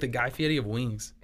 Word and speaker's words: Guy Fieri: It's pronounced Guy Fieri.